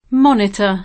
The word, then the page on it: monitore: monit 1 re